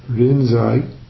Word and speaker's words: Rinzai: Linji is Chinese, or my poor attempt to pronounce the Chinese. And Linji in Japanese is pronounced Rinzai.